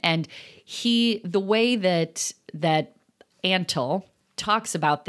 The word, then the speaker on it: Antle: So that's Doc Antle.